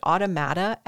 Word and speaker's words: automata: So this one is a 4 foot tall moving doll designed by a French, how do you say this word? Automata expert. – Sounds like you got it.